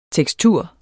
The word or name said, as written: tekstur